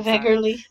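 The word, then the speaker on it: Wegerle: So, in this letter, he includes photocopies of photos he had taken of Vicky, how do we say it, Weggerly? Whatever. – I think. – And that's how I've heard it pronounced. – I just went full German on her last name.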